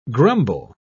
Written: grumble

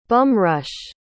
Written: bum-rush

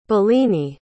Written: bellini

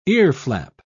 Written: earflap

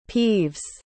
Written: peeves

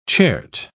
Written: chert

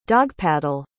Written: dogpaddle

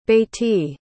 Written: beitidh

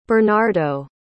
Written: bernardo

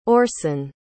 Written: orson

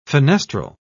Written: fenestral

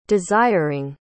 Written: desiring

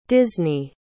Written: Disney

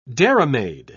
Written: dairymaid

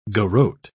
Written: garrote

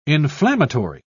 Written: inflammatory

